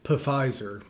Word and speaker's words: Pfizer: I like to pronounce it Pfizer, but that's just the proper way to say it.